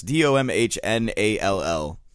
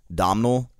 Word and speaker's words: Domhnall: D O M H N A L L. Domhnall. – Domhnall. – Domhnall. – Domhnall. – I think the H is silent.